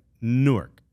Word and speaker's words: Newark: So it sounds like cork or fork without, but it's like, it's not Newark.